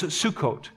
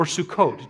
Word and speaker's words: Sukkot: It's also known as Sukkot. Okay, you might hear it that way, or Sukkot, depending on who's pronouncing.